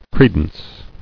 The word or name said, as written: Credence